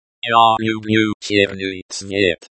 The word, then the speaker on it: черный: Sound in Russian is pronounced similar to the, ch, in the English word, chair. English, Russian black chair, черенерликаткое. – Черный.